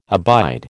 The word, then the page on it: abide: American English